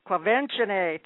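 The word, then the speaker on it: Quvenzhané: Kwevenzani, thank you.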